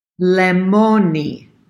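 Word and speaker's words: Lemoni (λεμόνι): So limone, sili. Did I say it wrong again? – Lemoni. – Lemoni. So it's got that double E on the end.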